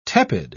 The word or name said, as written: tepid